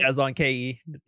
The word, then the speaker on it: ChazzonKe: ChazzonKe.